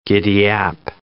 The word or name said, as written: giddyap